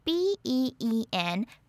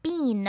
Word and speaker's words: been: oo 搞混咯。Foot 的 oo 是短音，它的音偏比较扁一点。Foot, foot，所以食物是 food, food，脚呢则是 foot, foot, food, foot。如果大家看得懂 KK 音标，可以去查查看字典，这两个字的 oo 它的音标其实是不一样的哦。再来，我们来看到 be 动词的过去分词 been, b e e n, been，它是发短音 i。It, been, been，要注意不要念成 bean 哦， bean 会是 b-e-a-n，就是豆子，所以不是 bean，是 been, been。